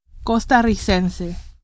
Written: costarricense

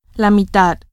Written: mitad

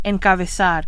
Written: encabezar

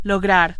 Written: lograr

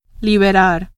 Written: liberar

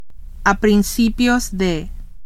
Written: a principios de

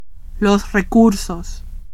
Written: recursos

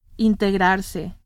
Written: integrarse

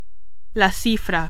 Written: cifra

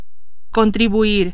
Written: contribuir

